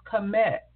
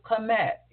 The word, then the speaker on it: Kemet: Their country was Kemet, or you can pronounce it Kemet, K-E-M-E-T.